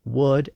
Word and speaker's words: would: That also helps to explain why words like would and should are spelled with an L, even though we don't pronounce those L's today.